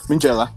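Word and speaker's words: Minghella: Mingela.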